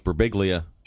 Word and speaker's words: Birbiglia: Joining us in the studio, comedian Mike Birbiglia. Mike, it's good to see you. – All right, one clap. – Is that the proper pronunciation, Mike? – Yeah, you can say whatever you want.